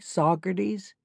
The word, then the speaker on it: Saugerties: That would be Dorothy Saugerties. And did I pronounce that correctly? Like the Hudson River town?